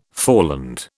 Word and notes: foreland: British English

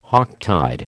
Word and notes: Hocktide: American English

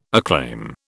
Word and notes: acclaim: British English

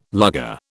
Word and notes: lugger: British English

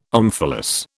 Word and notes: omphalos: British English

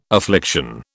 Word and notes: affliction: British English

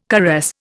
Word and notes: caress: Indian

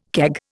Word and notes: keg: Indian

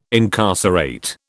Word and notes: incarcerate: British English

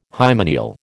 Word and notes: hymeneal: American English

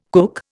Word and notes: kook: Indian

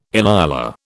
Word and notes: inyala: British English

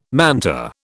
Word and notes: manta: British English

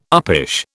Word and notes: uppish: British English

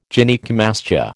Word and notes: Gynaecomastia: American English

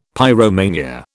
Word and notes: pyromania: British English

